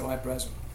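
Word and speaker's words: Hy-Brasil: And then I measured from Nazca to Eyebrazil, I mean, I think the Irish pronounce it Eyebrazil.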